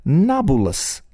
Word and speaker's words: Nablus: Napolis, because the Arabs have difficulty saying the letter B, is pronounced by the Arab-speaking peoples, Nablus.